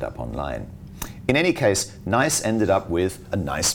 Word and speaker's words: Naiise: Now, in Singapore, this has once again become a particularly hot topic with the closure of one such chain called Nice. By the way, that is spelled N-A-I-I-S-E, in case you're looking it up on the internet.